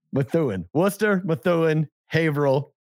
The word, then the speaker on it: Haverhill: No, H-A-V-E-R. Hover. – H-I-L-L. How do you say that? – H-I-L-L. Haverhill. – Haverhill. – Haverhill? – Haverhill. Yep. – Haverhill? One syllable? – Haverhill. One word. Haverhill. One syllable. Haverhill. Haverhill, Massachusetts.